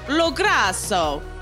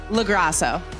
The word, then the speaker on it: LoGrasso: Like, if you were going to say it really Italian, it'd be like, LoGrasso. But American is LoGrasso.